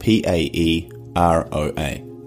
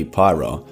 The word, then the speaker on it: Paeroa: That's spelled P A E R O A, and technically pronounced Paeroa or alternatively Pyro in the commonly used vernacular.